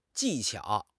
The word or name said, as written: 技巧